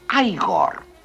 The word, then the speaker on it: Igor: Usted debe de ser Igor. – No, se pronuncia Igor. – A mí me dijeron que era Igor. – Pues estaban equivocados, ¿sabe?